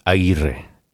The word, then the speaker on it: Aguirre: My name is TV, as in television, TEEVEE, Aguirre, AGUIRRE.